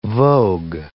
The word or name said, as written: vogue